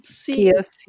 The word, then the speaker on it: Chios: And then a place called Emporio on the island of Chios, or possibly it's pronounced Chios. I don't know. – Chios, yeah. – I've heard it Chios, but I'm sure either way.